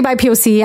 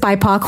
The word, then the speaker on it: BIPOC: Uh, and I don't know how what she calls them, but I, I, like to say by POC. I heard it's BIPOC. Who knows how you say it? Both ways.